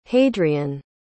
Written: hadrian

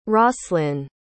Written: roslin

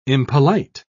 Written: impolite

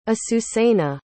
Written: azucena

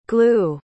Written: glew

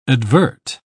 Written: advert

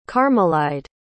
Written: carmelide

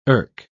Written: erk

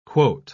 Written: quote

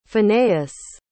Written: Phinehas

